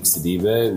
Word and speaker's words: Sidibe: My Instagram is my full name, Malik Sidibe, which is spelled as M-A-L-I-K-E. And my last name is spelled S-I-D-I-B-E.